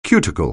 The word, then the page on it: cuticle: anglais